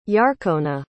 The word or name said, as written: yarkona